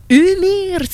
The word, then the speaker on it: Ymir: mais c'est un band de Finlande, mais en fait, on le prononce Umir, si c'était euh, en prononciation fine, mais ce que tu m'expliques, c'est un band euh, norvégien, donc évidemment le géant, le titan, c'est.